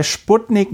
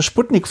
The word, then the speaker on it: Sputnik: Ähm, und sie hat mir erklärt, man würde das bei, bei Sputnik, man würde das mit wie so ein SZ aussprechen, das S. Also so ein sehr weiches S.